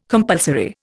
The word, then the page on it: compulsory: Indian